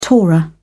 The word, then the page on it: torah: British English